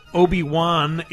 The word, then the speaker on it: Obi-Wan: So initially, he had it Obi-O-N-E, and then Kenobi spelled like K-I-N-O-B-I, and he's changed it. So Obi-Wan is now Juan, like Spanish Juan.